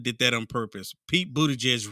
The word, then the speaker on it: Buttigieg: Yes, you have Pete Buttigieg.